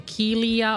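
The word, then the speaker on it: Kealia: Kelia Ohai? – Yeah, that's it.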